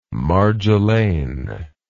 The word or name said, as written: marjolaine